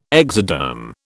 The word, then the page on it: exoderm: British English